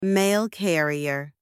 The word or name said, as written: mail carrier